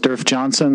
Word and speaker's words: Derf Johnson: Mr. Chairman, members of the committee, my name is Derf Johnson. That's spelled D-E-R-F-J-O-H-N-S-O-N, and I represent the Montana Environmental Information Center.